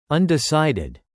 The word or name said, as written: undecided